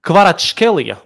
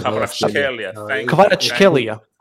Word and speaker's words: Kvaratskhelia: This Kvicha Kvaratskhelia, that's how you pronounce his surname. – Kvaratskhelia.